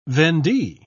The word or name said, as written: vendee